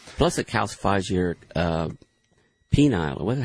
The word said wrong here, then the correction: pineal: How do you say that? – Pineal gland. – Pineal gland, that's it.